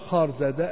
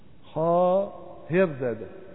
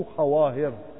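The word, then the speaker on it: خواهرزاده: وبه قال شيخ الإسلام المعروف بخار زادة، قلت خوار زادة، سمعت من شيخنا أنها تقرأ خار زادة، أي الواو لا تلفظ في لغة فارس، والله تعالى أعلم. خاهر زادة، في خاهر زادة يعني مو خواهر، الواو في لغة فارس تكتب ولا تلفظ،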